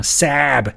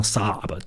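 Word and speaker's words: Saab: 那 sub 也一样，有一些美国人又把它读成 sab，哎，fuck that，好歹你也把它读成 sub，对吧？而且两个 a 在一起，你这怎么怎么想也不会是一个 a 的音啊。总之呢，sub 这个词就应该读成 sub。萨博翻译的音译基本上是准确的，萨博 sub 一模一样。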